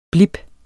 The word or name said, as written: blip